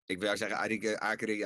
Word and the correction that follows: accreditatie: Akeri, ik kan het woord niet uitspreken. – Accreditatie. Accreditatie. Ja, dat ja.